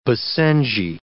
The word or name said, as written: basenji